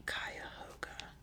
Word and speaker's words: Cuyahoga: It's pronounced Cuyahoga. Okay, earlier I said Cuyahoga. Apparently people say Cuyahoga River. I say Cuyahoga River. I don't know. Yeah, that is a thing though. People who are not from Ohio cannot pronounce our rivers.